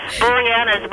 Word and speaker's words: Boreanaz: And David, I never say his name right, and he does Bones. Bori. How do you say his name? – I don't know. – Boreanaz, but he does.